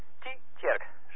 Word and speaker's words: Tietjerk: Nou, Tjerk, dat betekent trouwens kerk, Tjerk, maar Tietjerk. Zo, zo spreek je het uit, Hugo, hè? – Of, ik vind het moeilijk woord.